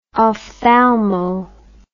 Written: ophthalmo

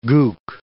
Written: gook

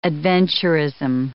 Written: adventurism

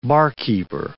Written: barkeeper

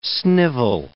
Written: snivel

